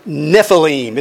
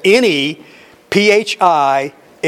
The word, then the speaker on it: Nephilim: Now the giants, the word giant is the Hebrew word Nephilim. It's spelled N-E, P-H-I-L-I-M.